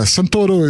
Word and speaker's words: Santoro: He shot all kind of celebrities, including the dude that was the bald guy in 300, Rodrigo Santoro. I don't know if my accent will... Yeah, Santoro is how we pronounce his name in Brazil.